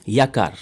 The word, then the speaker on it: Yakar: Alors, on va écouter Ankar, je le dis bien. – Yakar. – Yakar, ça veut dire ? – Yakar, c'est une chanson d'espoir, hein, voilà.